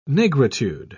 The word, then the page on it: nigritude: British English